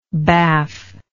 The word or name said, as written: baff